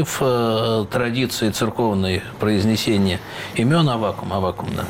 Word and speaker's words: Аввакум: Как все-таки правильно произносить? Я понимаю, что две нормы – Аввакум, как сейчас многие говорят, или Аввакум? – Древнерусская традиция – Аввакум.